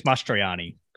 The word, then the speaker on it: Mastroianni: I thought we should have known more about the Mastro, how do you say his last name? – I think it's Mastroianni.